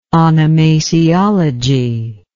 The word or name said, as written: onomasiology